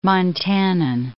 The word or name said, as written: Montanan